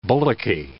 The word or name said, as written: bullocky